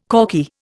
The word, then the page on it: corky: Indian